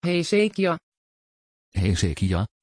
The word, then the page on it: hezekiah: Dutch